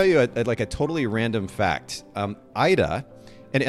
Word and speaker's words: Aira: It's spelled A-I-R-A, Ida. Huh. So like with the R is rolled, but it sounds very similar to your name.